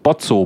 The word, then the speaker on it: Botzo: Wie man ihn spricht, Bozzo, B-O-T-Z-O, El Bozzo.